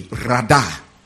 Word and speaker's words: radah: And it is translated from a Hebrew word, radah, as in R-A-D-A, radah, which means to tread down or to subjugate. Subjugate is spelled S-U-B-J-U.